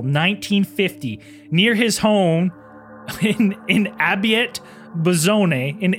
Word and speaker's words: Abbiate: I'm going to try to read it and then Abiate Buzone. – Sounds about right. – And now I'll spell it A-B-B-I-A-T-E. Abiate. – Abiate. – Abiate. – Abiate. – And then B-U-A-Z-Z-O-N-E.